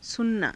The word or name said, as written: sunanaa